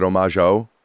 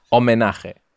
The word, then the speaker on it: homenaje: How do you say it in Spanish? – You say, that word just blank out of my mind. – If it's a guy, is it homage-o? – No. Homenaje.